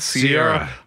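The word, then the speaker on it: Ciara: Now, what does this have to do with NFL quarterback Russell Wilson and his wife, Kiara? – Sierra. – How do you say it? Let's just ask her. – Sierra. Uh-huh. – So it's actually Sierra. Uh-huh. You have to say it like that.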